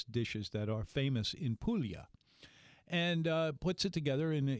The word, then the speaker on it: Puglia: Right now, Chef Andrea is featuring the food of Puglia, which is spelled P-U-G-L-I-A. In other words, here in New Orleans, we would call it Puglia. But the Puglia, that's how they say it.